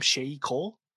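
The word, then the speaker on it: Sheyi Cole: I thought, I don't know, how do you say the lead actor's name? Shay Cole? – Yep.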